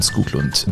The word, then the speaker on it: Skoglund: Und Skoglund, in Schweden sagt man Skoglund. Das ist einer also der... – Also dieses O mit dem Strich nein, durch? Nein, nein, nein. Das ganz normale O im Schwedischen wird wie U ausgesprochen.